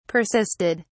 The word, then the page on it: persisted: British English